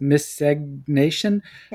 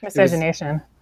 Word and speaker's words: miscegenation: how do you pronounce it? Miscegnation? – Miscegenation.